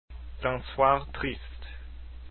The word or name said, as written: d'un soir triste